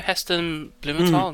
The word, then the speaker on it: Heston Blumenthal: You know Heston Blumenthal.